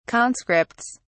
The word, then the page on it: conscripts: British English